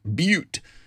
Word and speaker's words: Butte: Okay, now, if you're traveling through the wonderful blue sky state of Montana, keep in mind it's pronounced Butte, Montana, not but.